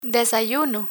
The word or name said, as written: desayuno